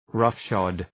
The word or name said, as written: roughshod